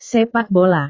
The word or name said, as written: sepak bola